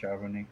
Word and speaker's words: Charbonnet: Yeah, Charbonnet would have been, or how do you pronounce his surname? Charbonnet. He would have been the next one. Yeah, you got it. You nailed it. – Charbonnet.